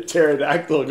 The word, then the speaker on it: pterodactyl: Why is it impossible to hear a pterodactyl going to the bathroom? – Why? – Because the pee is silent. – Wait, what? – No. Oh, is there a pee in pterodactyl? – Yes.